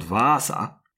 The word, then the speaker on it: Vaasa: So speed so high that more than 200 kilometers further north from Pori, in a place called Vaasa, I'm not sure I pronounced the name properly. – Sounds right, yeah.